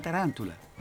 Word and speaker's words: tarántula: La tarántula. Lleva acento en la segunda...